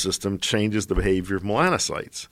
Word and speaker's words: melanocytes: Melancholites? How do you say it? – Melanocytes. – Melanocytes.